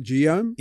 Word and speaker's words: Guillaume: Sounds a bit Guillaume, if I'm pronouncing it right. Guillaume? – Yeah, French. Yeah.